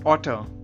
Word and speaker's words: auteur: Our first word today is otter. Otter is spelled as A-U-T-E-U-R.